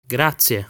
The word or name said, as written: Grazie